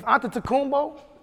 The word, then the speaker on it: Antetokounmpo: If Antetokounmpo, I said it right. – Yes, sir. – yeah, if Giannis Antetokounmpo came on the court, in the 70s, they would sue his ass.